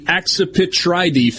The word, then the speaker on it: Accipitridae: The Mississippi kite is part of the Accipitridae family.